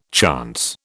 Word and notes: chance: British English

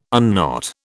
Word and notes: unknot: British English

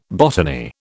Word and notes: botany: British English

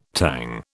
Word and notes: Tang: British English

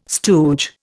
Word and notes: stooge: Indian

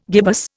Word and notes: gibbous: Indian

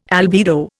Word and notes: albedo: Indian

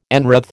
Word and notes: enwreathe: American English

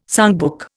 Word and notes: songbook: Indian

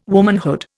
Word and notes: womanhood: Indian

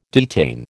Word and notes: detain: American English

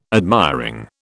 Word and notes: admiring: British English

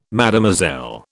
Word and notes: Mademoiselle: British English

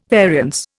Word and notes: variance: Indian

